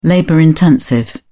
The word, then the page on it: labor-intensive: British English